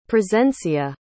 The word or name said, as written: presencia